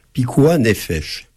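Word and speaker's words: pikuah néphèche (פיקוח נפש): Alors il y a un principe dans le judaïsme, pikuah néphèche.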